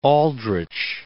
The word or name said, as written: Aldrich